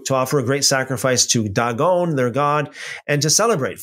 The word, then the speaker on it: Dagon: Dagon, by the way, Dag, pronounced as dog in Hebrew, means whole thing.